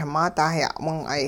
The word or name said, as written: maa dah ya'wing'ay